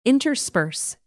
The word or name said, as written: intersperse